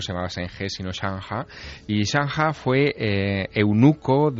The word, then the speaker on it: Zheng He: Allí no se llama Zheng He, allí se llama Chang Ha, que es...